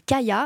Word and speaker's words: Kaia: utilise une toute nouvelle marque tunisienne qui s'appelle Kaya, alors ça s'écrit K-A-I-A, qui est faite à base de la variété Chemlali.